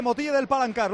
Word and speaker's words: Motilla del Palancar: Y con el gladiador de Motilla del Palancar.